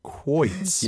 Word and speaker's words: quoits: I want to make sure you don't pronounce an extra syllable in there because that's not a good thing. We're just going to leave that there. – Right there. Quoits. – Yeah. – And it's pronounced like K-W, quoits.